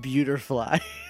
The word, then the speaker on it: Beautifly: It's pronounced beautifly.